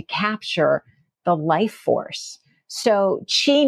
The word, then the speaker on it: qi: There, when we talk about qi, so depending on which specific culture we're in, depends on how we spell. So there's qi, there's chi, there's ki, and it all is pronounced qi.